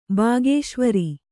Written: ಬಾಗೇಶ್ವರಿ

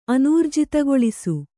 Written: ಅನೂರ್ಜಿತಗೊಳಿಸು

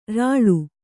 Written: ಱಳ

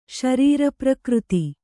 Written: ಶರೀರಪ್ರಕೃತಿ

